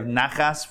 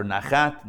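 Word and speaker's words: nachas (נחת): You ever hear somebody saying they have nachas from their kids? Nachas means pride. That's how that word is pronounced, or nachat, nachas.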